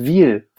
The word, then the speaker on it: Wyhl: Wiel wird es ausgesprochen, ja. – Aber es wird mit YHL Ja, ja, ja.